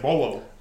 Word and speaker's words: Paulo: Like how Junior Paulo, that's Junior Bolo. Bolo. – Bolo. – Yeah, Bolo. Yeah, Bolo. I don't think it's Bolo. – Yeah, it is. It is. Yeah, it's pronounced Bolo.